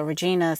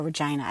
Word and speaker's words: Regina: So Regina, Saskatchewan is where they were going. Or Regina. I think it's Regina is how they say it.